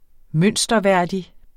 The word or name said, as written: mønsterværdig